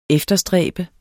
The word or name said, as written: efterstræbe